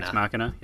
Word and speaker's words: Ex Machina: But, you know, Ex Machina. Wait, how do you say that? Machina? What do you? – I think that's right. – Machina. – Ex Machina.